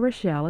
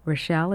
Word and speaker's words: Rochelle: My name, Rita Rochelle, is spelled R-I-T-A. Rochelle is R-O-C-H-E-L-L-E.